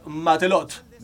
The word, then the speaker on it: matelot: Del francés matelot, o marinero.